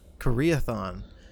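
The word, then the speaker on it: Karaethon: I'm going to butcher this pronunciation, but the Karathon cycle, the prophecies Koreathon. Of the Koreathon.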